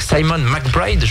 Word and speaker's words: McBride: C'est pour ça que vous étiez à bloc avec Simon McBride, je le dis bien.